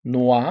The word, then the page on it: noix: son wa